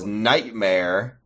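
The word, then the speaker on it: Knightmare: That K is silent. Her name is sorry, his name. Oh, it's Nightmare with a K.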